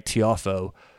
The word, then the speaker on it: Tiafoe: I want to get the pronunciation right, Tiafoe.